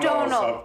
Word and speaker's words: Domhnall: I love Domhnall. His name's Domhnall. – That's Domhnall. – It's pronounced Domhnall. – Domhnall. there's an M-H-N. It's Domhnall.